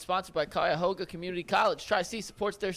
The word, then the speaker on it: Cuyahoga: I just asked Anthony how to say it. – Yeah, but now you forgot it. – And they both said something different, so it really threw me off. But it's Cuyahoga.